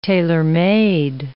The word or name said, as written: tailor-made